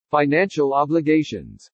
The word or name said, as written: FINANCIAL OBLIGATIONS